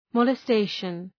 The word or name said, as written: molestation